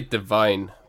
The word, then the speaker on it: Devine: I say Devine. Because it's like Kevin Devine, who I'm wearing his t-shirt right now, who's one of my favorite singer-songwriters, and I assumed it was like that, but it may well be Devine.